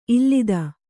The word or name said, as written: ಈಲಿತ